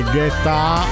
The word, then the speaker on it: Guetta: So che c'è la regola non scritta, forse pure scritta, non puoi pronunciare David Guetta o David Guetta, che dir si voglia, insomma, nome in una lingua, cognome in un'altra. Vabbè, vai, il cognome è italiano, però dovrebbe essere pronunciato in francese.